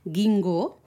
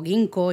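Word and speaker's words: ginkgo: Así que mira, me he ido, ya que te ha reído un poco de mí, de mi pronunciación, de que siempre tengo problemas para pronunciar gingo, que siempre digo ginko y cosas de esas, eh, pues mira, he escogido justamente esa palabra, la palabra gingo en japonés, que es icho.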